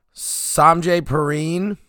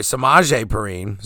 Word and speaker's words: Samaje Perine: Samaje Perine, Or sorry, Samaje Perine. Sorry, Samaje. I pronounced your name wrong.